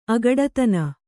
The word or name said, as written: ಅಗಡುತನ